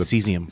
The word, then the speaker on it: caesium: So a week after that event, meteorological officials detected unusually high levels of caesium, 25 miles southwest of the... – Cesium. – Cesium. It's C-A-S-E-I-U-M. Mm-hmm. It's pronounced cesium? Mm-hmm.